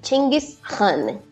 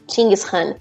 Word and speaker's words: Chinggis Khan: Aqui, a pronúncia local seria Chinggis Khan. Chinggis Khan.